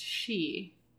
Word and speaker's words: Sidhe: Is like, oh yeah, it's spelled A-I-N-E, but it's pronounced Anya. Mm. You're like, I'm sorry, what? Or it's spelled S-I-D-H-E, but it's pronounced she.